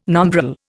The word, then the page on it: nombril: Indian